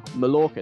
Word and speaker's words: Mallorca: They are, because in Spanish you pronounce the double L as a y. Therefore, Mallorca is actually pronounced Mallorca.